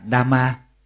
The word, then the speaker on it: Dhamma: Thì xin nhắc lại, cái chữ Pháp đó, theo tiếng Phạn đọc là Dhamma. Tiếng Pali đọc là Dhamma, còn tiếng Phạn, tiếng Sanskrit đó, đọc là Dharma.